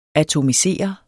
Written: atomisere

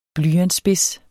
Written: blyantsspids

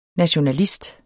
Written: nationalist

